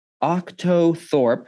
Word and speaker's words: Octothorpe: Octothorpe, which has an E at the, spelled like it sounds with an E at the end.